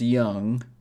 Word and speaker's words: Jung: The last name is spelled J-U-N-G. I believe it's pronounced Jung or Jung.